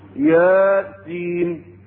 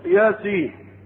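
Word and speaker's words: يس: هي لا تنطق إلا كما نطقها أنا، ياسين، يعني أنا أنطق حرف ثم حرف، هم قالوا لا هي ياسين، وطيب يعني قال ده إيه؟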